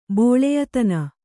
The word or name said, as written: ಬೋಳೆಯತನ